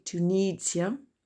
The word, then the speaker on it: Tunisia: Tunisia, attention l'accent tonique, Tunisia.